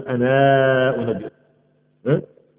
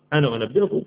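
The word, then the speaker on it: أنا أنبئكم: ف وقال الذي نجا منهما وذكر بعد امه انا انبئك، انا طبعا عندنا شويه اخ الالف بتاعت انا لا تنطق، لو كنا ورش كانت تنطق وتطيب كمان، يعني ورش كنا هنقول انا انبئكم، لكن هنا في حفص انا انبئكم فقضايا كتير لغويه، لان يقولوا ان الضمير هو النون بس او انا بس والالف دي كذا ناخده، انا انبئكم بتاويله فارسلون.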